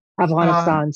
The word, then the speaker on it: Afghanistan: Well, first, I have to say, I love how you say Afghanistan. – I'm trying. – It's really beautiful. It's a very authentic way of saying it, Afghanistan. So I applaud you for knowing how to pronounce that.